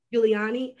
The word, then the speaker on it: Giuliani: And also Donald Trump's, his private lawyer, Rudy Giuliani. Did I pronounce that right? – Giuliani.